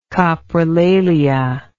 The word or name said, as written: coprolaliac